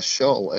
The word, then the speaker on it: Shull: It's Jonathan Shull, or how do you say your last name? – Uh, Shull, S H U L L.